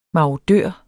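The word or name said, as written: MARODØR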